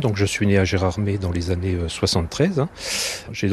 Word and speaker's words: Gérardmer: Attention, ne prononcez pas Gérardmer, ça irrite les habitants, les Géromois.